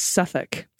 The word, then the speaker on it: Southwark: Okay, I said Sowork, but it's Suffolk.